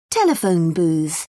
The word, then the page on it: telephone booth: British English